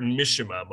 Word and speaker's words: Mishima: How do you say Mishima? Mishima? – I've heard Mishima mostly.